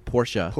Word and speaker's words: Porsche: Porsche.